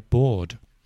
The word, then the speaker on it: board: But the spelling is different. The pronunciation is exactly the same. But this board we're referring to, as in a board game, is B-O-A-R-D, not B-O-R-E-D.